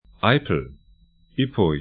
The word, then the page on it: Eipel: 'aipl